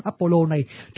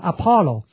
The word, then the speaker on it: Apollo: Người Việt Nam mình có lẽ không bao giờ nghe tên thần Apollo này, trừ ra nghe trong cái hỏa tiễn. Apollo này tiếng Pháp đọc là Apollon, còn ở trong tiếng Anh nó đọc là Apollo.